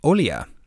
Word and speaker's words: Olija: And then the last one was actually a game that came out today called Olia, and it's spelled not really like how it sounds, or at least, you know, in English pronunciation and stuff. It's Olija, so kind of the YA sound of, I think places like, what, like maybe like Sweden and stuff like that?